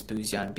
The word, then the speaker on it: Buzyan: My handle is P, and then my last name is Buzyan, Buzyan.